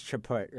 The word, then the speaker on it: Chaput: I think it's Chaput, right? – Chaput, yeah.